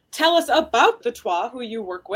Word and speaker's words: Twa: And now correct me if I'm pronouncing this wrong, the Twa? – Right, Ovatwa, the Twa.